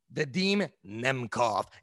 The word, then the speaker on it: Vadim Nemkov: Vadim Nemkov.